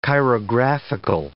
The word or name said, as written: chirographical